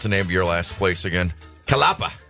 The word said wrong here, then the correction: Catalpa: Catalpa Health. – Catalpa. It's a tree.